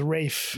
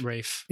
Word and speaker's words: Ralph: So I guess the L is Rafe. – Rafe.